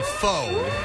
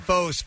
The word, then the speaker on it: faux: So, as in faux. He's wearing faux fur, which is spelled F-A-U-X, and she thought that was an animal.